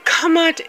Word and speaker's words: Kemet: When you hear Kamat, Kamut is spelled K-M-T, K-M-T, Kamut, Kamut. Also pronounced and called, they will spell it K-E-M-E-T, but it's Kamut.